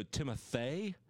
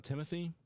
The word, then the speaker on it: Timothée: Also, Timothée, I believe, not Timothy. – What? – There was an article that he said it's actually pronounced Timothée Chalamet.